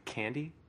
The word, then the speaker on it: Kandi: So this is made by the Chinese company Candy, I presume is how that's pronounced, K-A-N-D-I.